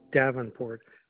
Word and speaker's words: Davenport: It's Paul Davenport.